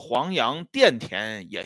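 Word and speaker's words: 黄杨钿甜: 那么在这种情况下，我们看到啊，有一位演员，非常年轻的演员， 18岁吧，他这名字起的也很有意思啊，叫黄杨甜甜也行，叫黄杨电甜也行，他是个多音字啊，这个金字旁加一个田地的田。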